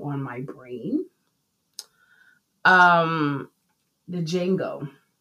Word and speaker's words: Django: No, it's Django. That's it. The D is silent.